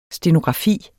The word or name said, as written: stenografi